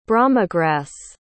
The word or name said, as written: bromegrass